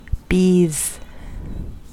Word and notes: bees: American English

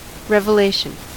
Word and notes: revelation: American English

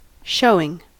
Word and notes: showing: American English

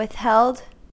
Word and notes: withheld: American English

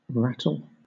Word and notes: rattle: British English

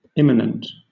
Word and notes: immanent: British English

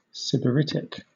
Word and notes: sybaritic: British English

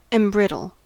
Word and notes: embrittle: American English

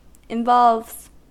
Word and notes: involves: American English

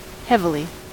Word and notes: heavily: American English

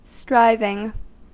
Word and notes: striving: American English

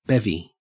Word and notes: bevy: Australian English

